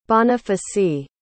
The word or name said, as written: Bonifacy